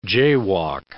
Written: jaywalk

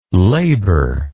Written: labour

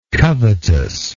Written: covetousness

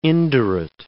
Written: indurate

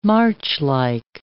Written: marchlike

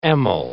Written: amyl

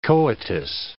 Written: coitus

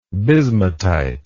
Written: bismutite

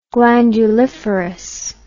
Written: glanduliferous

